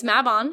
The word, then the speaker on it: Mabon: to tell us the correct pronunciation of Mabon. – That's it. – It's Mabon.